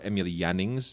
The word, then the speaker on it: Emil Jannings: Marlene Dietrich and, of course, Emil Jannings, Emil Jannings, if you want to do the German pronunciation, who, of course, was a big deal in American films at the time as well.